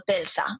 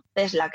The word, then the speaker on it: Tesla: Bueno, siempre lo digo mal, no sé si es Tesla o Telsa. Tesla creo que es.